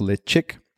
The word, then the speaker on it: Ledaig: So again, Ledaig is pronounced Lechick.